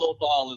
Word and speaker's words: Lothal: Lothal. Yeah, pronounce it so many different ways. Lothal. Yeah. I think Lothal is like the most common.